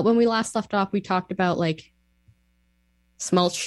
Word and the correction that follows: schmaltz: How do you say this word? – Schmaltz. Yeah, it's a sh sound.